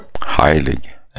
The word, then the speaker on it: Heilig: Hur man uttalar hans namn, det har jag aldrig förstått faktiskt. I svensk eller tyskt uttal? – Heilig.